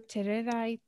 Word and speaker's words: Tererai: It's Dr. Tererai Trent. – Can you say it again? – Tererai.